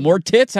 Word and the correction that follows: Moritz: how do you say his name? – Moritz. – Moritz, my apologies. It looks like it's spelled that way.